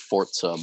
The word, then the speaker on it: Forza: And the thing about that is, one, I don't know about it's pronounced Forza, by the way, if you didn't know. – Forza. – They literally tweeted it out, and they were like, by the way, it's Forza, in case you were wondering, like, maybe like 2 months ago.